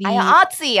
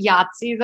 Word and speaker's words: IATSE: IATSE, I like that. – I know some people pronounce it Yahtzee, though, and that's weird.